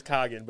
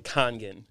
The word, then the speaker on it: Kangen: Yeah, it's always Kagan, but it's supposed to be like Kangen. – Kangen. There's a fucking N in there. – You gotta pronounce that N. It's very important to me. – I know it is. – There's some N's you pronounce and some N's you don't. – Yes. – Yeah. It's a soft N.